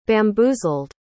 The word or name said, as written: bamboozled